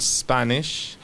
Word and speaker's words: Spanish: Español, Spanish. Cuidado al decir español, porque se escribe S-P-A-N-I-S-H. No hay que decir la E, porque siempre tendemos Spanish.